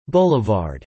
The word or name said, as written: Blvd